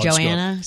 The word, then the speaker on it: Joanna: You know, Chip and Joanne. – Yeah, might as well. – Joanna, sorry if I said her name wrong.